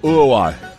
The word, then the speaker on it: Uruguay: I pronounce all vowels when I pronounce Uruguay, and it annoys my wife so much. I go, Uruguay. You know, like I just don't even say anything. – Right. – It's just all U's and Y's.